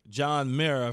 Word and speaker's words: John Mara: Because in 2017, when the whole kneeling and Kaepernick was such a hot button issue, the New York Giants and co-owner John Mara, if I'm saying it correctly.